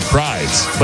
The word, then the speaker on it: Prydz: Is it Eric Prydz? – Yeah, I'm playing. It's Prydz. – P-R-Y-D-S.